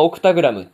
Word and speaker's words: オクタグラム: 八人の星の魔王と書いて、まあ、オクタグラムっていう、まあ、読み方をさせるわけですが、